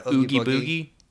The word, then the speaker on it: Oogie Boogie: But everyone loves Oogie Boogie. Mm-hmm. Am I saying that right? – Yeah, Oogie Boogie.